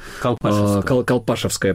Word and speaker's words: Колпашевское: Не все, может быть, не все наши слушатели знают историю Колпашевского Яра. – Колпашевское, правильное ударение.